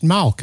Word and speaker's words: malk: In biblical times, it was pronounced Malk, and we write it M-A-L-K.